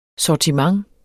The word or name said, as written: sortiment